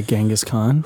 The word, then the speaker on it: Genghis Khan: Genghis Khan. – Yeah, Genghis Khan.